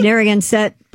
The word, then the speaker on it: Narragansett: The Narragansett, how do you say this? Narragansett Police Department.